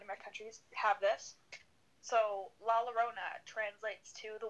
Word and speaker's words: La Llorona: Remember last time I said it's like Corona with L's. And there's two L's in LaLorona. It's like La and then LLona.